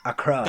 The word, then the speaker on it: Accra: Accra, that's the one. – Accra.